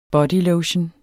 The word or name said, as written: bodylotion